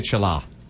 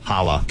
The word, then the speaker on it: challah: C-H-A-L-L-A-H. – Let me see it. – Right there. – I was gonna say chala. – Chala? – Hala. – I feel like it's a trick, and I bet it's no age. Kala. – Hala.